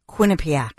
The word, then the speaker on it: Quinnipiac: How do you say that? – Quinnipiac. – Quinnipiac? – Quinnipiac.